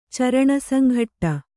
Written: ಚರಣಸಂಘಟ್ಟ

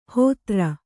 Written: ಹೋತೃ

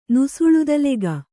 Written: ನುಸುಳುದಲೆಗ

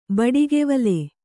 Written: ಬಡಗಿವೊಲ

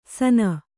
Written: ಸನ

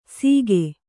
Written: ಸೀಗೆ

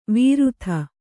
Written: ವಿರುತ

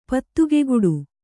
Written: ಪತ್ತುಗೆಗುಡು

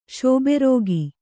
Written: ಶೋಬೆರೋಗಿ

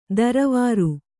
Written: ದರವಾರು

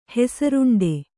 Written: ಹೆಸರುಂಡೆ

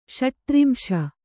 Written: ಷಟ್ತ್ರಿಂಶ

